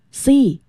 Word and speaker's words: sea: I like being near the sea， sea 在这里和看到的那个 sea S-E-E 发音是一样的，但是呢意思却完全不同， S-E-A 就是海洋。